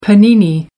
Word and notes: panini: American English

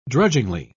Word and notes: drudgingly: British English

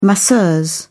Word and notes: masseuse: British English

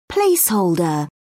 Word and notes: placeholder: British English

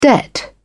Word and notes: debt: British English

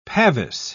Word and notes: pavis: British English

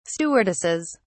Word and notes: stewardesses: British English

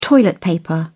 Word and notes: toilet paper: British English

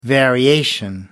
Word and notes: variation: American English

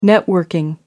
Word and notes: networking: British English

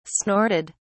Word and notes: snorted: British English

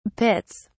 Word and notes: pits: British English

